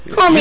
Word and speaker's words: Cormier: Cormier.